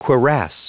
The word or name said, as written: cuirass